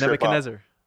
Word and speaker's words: Nebuchadnezzar: I always, the ship name, I always trip Nebuchadnezzar.